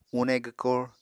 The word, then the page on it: une gwkor: Apatani